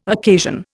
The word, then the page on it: occasion: Indian